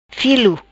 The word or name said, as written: Filo